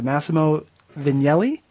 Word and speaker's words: Massimo Vignelli: September 12th, uh, the uh, a lecture by Massimo. I'm, I'm hoping I'm pronouncing his name correctly. Massimo Vignelli. Yep.